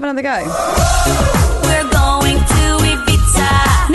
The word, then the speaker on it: Ibiza: How do you say Ibiza? – I think she says Ibiza, doesn't she? – Play it again. – Ibiza. She says Ibiza.